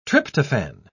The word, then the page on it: tryptophane: British English